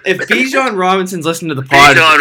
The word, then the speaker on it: Bijan: So I want to say I pronounced Robinson's first name wrong. – Yeah, I thought you did. I couldn't tell if you said Brian or not, but it's Bijan. Bijan. – Yeah, Bijan, Brian, I apologize.